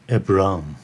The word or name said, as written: Abram